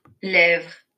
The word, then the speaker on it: lèvres: Lèvres.